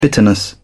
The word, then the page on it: bitterness: British English